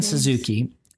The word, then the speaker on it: Satsuki: Satsuki. I think the T is silent. Mei and Satsuki, who are moving with their father into a house in the countryside.